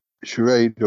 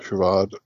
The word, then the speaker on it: Charade: And Charade or Charade, depending how you want to pronounce it, from 63.